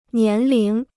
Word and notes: 年龄: nián líng

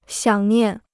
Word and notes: 想念: xiǎng niàn

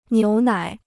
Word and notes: 牛奶: niú nǎi

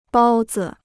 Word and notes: 包子: bāo zi

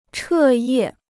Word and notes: 彻夜: chè yè

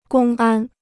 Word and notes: 公安: gōng ān

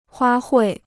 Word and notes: 花卉: huā huì

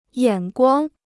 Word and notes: 眼光: yǎn guāng

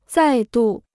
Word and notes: 再度: zài dù

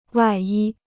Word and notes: 外衣: wài yī

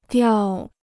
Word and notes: 掉: diào

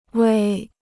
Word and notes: 胃: wèi

